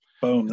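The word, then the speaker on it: Boehm: Is that the way you pronounce her name? – Bohm, that's right.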